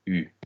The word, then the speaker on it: eu: So if you see EU on its own, this is the past participle of the verb to have, and it will be pronounced U, as in j'ai eu, I've had.